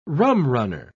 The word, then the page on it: rumrunner: British English